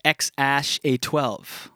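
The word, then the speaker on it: X Æ A-12: It's actually, it's pronounced X-A-12.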